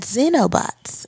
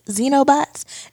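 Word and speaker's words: xenobot: So, um, apparently, there's a form of robot called Xenobots. Xenobots. Listen, it's spelled X E N O bots.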